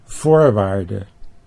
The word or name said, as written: voorwaarde